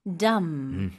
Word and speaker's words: dumb: Dumb. Dumb. – D-U-M-B인데 쌤 방금 덤브라고는 안 했잖아요. – 맞아요. B는 완전히 무음이에요. 살짝이라도 발음 안 하시는 게 좋아요.